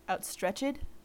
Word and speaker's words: outstretched: Is, I believe, pronounced outstretched here. That's a thing that happens in a lot of Shakespeare's speeches. – Yeah. – The ED is emphasized.